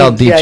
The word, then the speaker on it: El Dicho: He pronounced it like Rican. Dicho. – Dicho. – El Bicho. – El Dicho. – I mean, El Dicho. Yeah, I'm sorry.